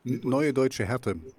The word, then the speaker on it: Neue Deutsche Härte: I'm going to say it wrong, but New Deutsche Härte? – Neue Deutsche Härte. Yeah, I know what. NDH. Just say NDH. Neue Deutsche Härte. This is New German Hardness.